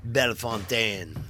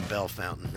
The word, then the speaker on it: Bellefontaine: You want to say Bellefontaine rather than Bellefountain, how they pronounce it.